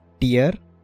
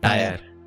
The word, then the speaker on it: tier: This is like you pronounce tier as tier. – Yeah, yeah. – I used to pronounce it as tire.